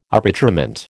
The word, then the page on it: arbitrament: American English